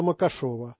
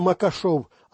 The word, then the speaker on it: Макашов: Если вы переставите в его фамилии ударение не Макашов, а Макашов, то станет ясно, что это фамилия из того же кавказского ряда, что и Махашев, коих бесчисленное множество.